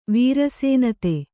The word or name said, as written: ವೃಷನಾಥ